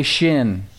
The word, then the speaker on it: shin: Say shin. Not like your shin, but it's pronounced that way.